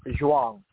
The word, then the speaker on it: Zhuang: Zhuang. Z-H-U-A-N-G.